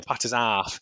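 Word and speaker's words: Potter's Arf: Have you ever, well, off, it's spelled ARF, so the Potter's Half.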